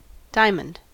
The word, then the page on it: diamond: American English